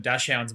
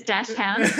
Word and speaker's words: Dachshund: Everybody says Dachshund. The German pronunciation is Dachshund. – Dachshund. But how are you saying it? Are you kind of saying that faster or something? – I do the Aussie version, Dachshund.